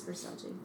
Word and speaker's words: Versace: Or Versace, whatever, however you say it. I'm pronouncing it wrong. – Versace. – It's Versace.